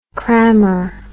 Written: crammer

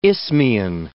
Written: isthmian

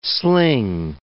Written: sling

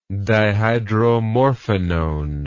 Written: dihydromorphinone

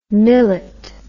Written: millett

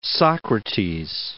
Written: Socrates

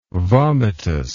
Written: vomitous

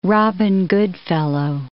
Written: Robin Goodfellow

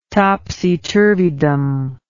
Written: topsy turvydom

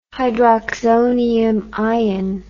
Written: hydroxonium ion

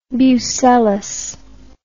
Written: bucellas

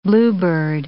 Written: bluebird

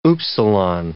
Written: upsilon

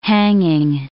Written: hanging

